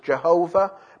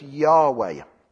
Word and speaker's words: Yahweh: In the past, we thought that maybe the name was pronounced as Jehovah, but now the scholars think God's name was Yahweh.